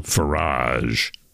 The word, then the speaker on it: Farage: Nigel, in case you're wondering, refers to the leader of the Reform Party, a controversial, to put it mildly, politician called Nigel Farage. Rhymes with garage or fromage.